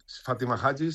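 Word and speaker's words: Fátima Hajji: Claro, Fátima Haji, no sé si lo he dicho bien. – Fátima Haji, sí.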